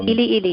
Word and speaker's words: Ili Ili: And so, like, when I listen to Ely Ely, if I'm saying that right. – Yeah, Ely Ely, yeah. – I-L-I-I-L-I, when I listen to it.